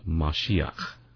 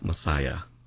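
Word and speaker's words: Mashiach (משיח): And this particular Hebrew letter is pronounced ch. It's a guttural. And so you've got the KH here representing that guttural sound pronounced Mashiach. Mashiach. Not Messiah or Messiah. That's a Englishism, okay?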